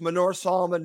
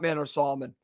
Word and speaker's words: Manor Solomon: And that's actually the correct pronunciation. It's Manor Solomon, not Manor Solomon. Just wanted to share that with everyone in case you are interested. That's the way it's supposed to be pronounced.